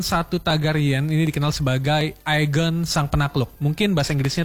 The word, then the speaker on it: Aegon: Film ini juga ngangkat kisah penaklukan Westeros oleh Agent One Targaryen ya, semoga gue pronounce-nya bener ya. Aegon.